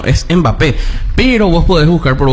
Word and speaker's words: Mbappé: pero lo cierto es que la correcta pronunciación de su apellido es Mbappé, tal cual, como Mbeyú.